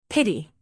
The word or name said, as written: pity